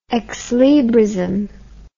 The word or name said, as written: exlibrism